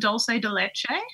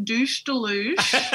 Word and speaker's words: dulce de leche: And it wasn't until the end of that week that the woman working there took me aside and she said, actually, love, I've just got to tell you, it's pronounced dulce de leche. And I've been asking for a scoop of douche deluge for a whole week.